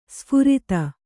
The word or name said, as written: ಸುಫರ್ದು